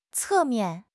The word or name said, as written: 侧面